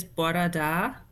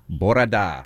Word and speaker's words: bora da: Good morning is bora da. – Bora da.